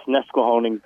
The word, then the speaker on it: Nesquehoning: Yeah, it's Nesquehoning, Pennsylvania. – Nesquehoning, Pennsylvania.